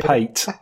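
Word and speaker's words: pâté: And anyway, it's oh, pronounced oh, pate.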